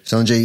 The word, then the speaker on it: Sungjae: How do you say his first name? – Sungjae Im.